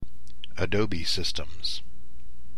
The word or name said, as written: Adobe Systems